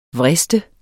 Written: vriste

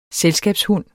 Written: selskabshund

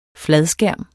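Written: fladskærm

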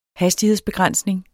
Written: hastighedsbegrænsning

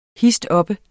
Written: histoppe

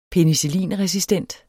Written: penicillinresistent

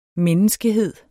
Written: menneskehed

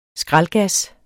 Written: skraldgas

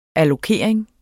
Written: allokering

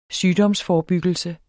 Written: sygdomsforebyggelse

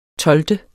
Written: tolvte

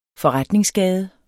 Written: forretningsgade

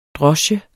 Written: DROSKES

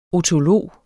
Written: otolog

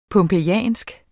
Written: pompejansk

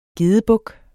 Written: gedebuk

